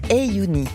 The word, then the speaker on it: 3YOONI: Son nom s'écrit 3-Y-O-O-N-I, mais ça se prononce Ayouni. Le premier son, c'est en fait une lettre arabe qui se dit Ay et qui ressemble à l'écrit au chiffre 3.